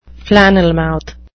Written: flannelmouth

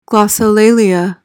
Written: glossolalia

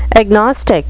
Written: agnostic

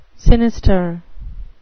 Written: sinister

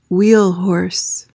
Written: wheel horse

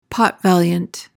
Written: pot-valiant

